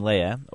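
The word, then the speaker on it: Löhe: Is it A, the commemoration of Basil the Great, the commemoration of Pastor William Lea, or as my German seminarian friend tells me to pronounce it, Wilhelm Le.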